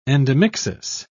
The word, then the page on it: endomixis: British English